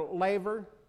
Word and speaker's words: laver: But this bronze, how do you say, laver, there you go.